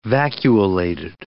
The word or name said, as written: vacuolated